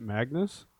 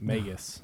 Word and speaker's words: Magus: Is it Magnus? – I don't see an N in there. – Magus.